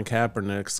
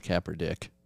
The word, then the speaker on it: Kaepernick: Here's what I would say, regardless of where you stand on outside of football, you would think Colin Kaepernick's got to be better than 64 other guys, which would put you into the backup role. – You pronounced his last name wrong. – What? – It's Kaepernick.